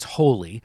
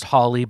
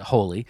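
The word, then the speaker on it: Hawley: I think it's Holy. Some say it's pronounced Holly, but I think he pronounces it Holy.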